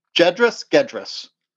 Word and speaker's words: Gedris: G-E-D-R-I-S. So is this like George, or is this a giraffe, or is it more like Gary? – Well, it's pronounced GIF, so let's go with the GIF. – See, and again, you're wrong. So Gedris it is.